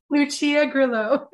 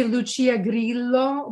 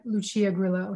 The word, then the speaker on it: Lucia Grillo: Because that's pronounced Italian, that name, like yeah. – That. – Yeah, it would be Lucia Grillo, but Lucia Grillo, yeah, anglicized.